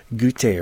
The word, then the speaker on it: Guite: It's all about Christmastide, and he's incorporated a fair bit of poetry there from Malcolm Gute, or G-U-I-T-E is how the last name is pronounced, and pulls it all together very nicely.